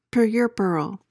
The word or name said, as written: puerperal